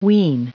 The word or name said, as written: wean